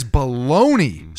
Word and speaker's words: bologna: No, why is bologna spelled that way? – With a G. – Bologna. – And cologne. – Where do you get bologna out of that? – And lasagna. – The G is silent.